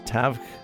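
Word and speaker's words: Tadhg: Opener will be, I have to get the pronunciation of Celtic back in my head because it's T-A-D-H-G, so I think that's Tavg Nolan.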